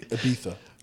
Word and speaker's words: Ibiza: It's Ibiza. – Oh, yeah. That's Ibiza. – And if we're pronouncing it natively, Ibiza.